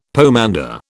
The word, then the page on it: pomander: British English